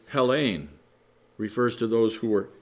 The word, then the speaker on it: Hellene: Thus, the Greek word Hellen is used. It's pronounced Hellene in the Greek.